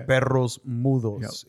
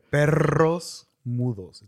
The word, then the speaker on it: perros mudos: Perros mudos. Yep. If you can't roll your R's in Spanish, just give it a shot. – Yeah. – Perros mudos.